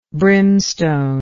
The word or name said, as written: brimstony